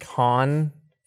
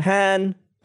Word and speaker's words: Han: And Khan or Han? – Han, Princess Leia.